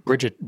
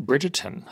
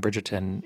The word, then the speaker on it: Bridgerton: Bridget Bridgerton.